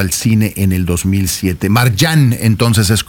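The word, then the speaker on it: Marjane: Marjan, me dicen que es como debo pronunciarlo.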